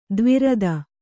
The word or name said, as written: ತ್ವರಿತ